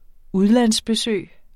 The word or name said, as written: udlandsbesøg